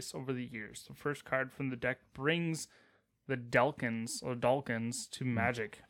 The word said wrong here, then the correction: Daleks: It's pronounced Daleks.